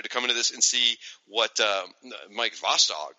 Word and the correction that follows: Vosburg: or how do you say his name? – Mike Vosburg. – Bossberg. That's right.